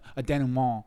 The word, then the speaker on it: denouement: What bothered me was like I wanted more of a denouement with nice pronunciation.